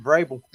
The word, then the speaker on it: Vrabel: Always said it right, Vrabel.